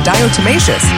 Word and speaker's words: diatomaceous: It's a funny-looking word, but it's pronounced diatomaceous.